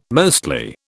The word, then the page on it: mostly: British English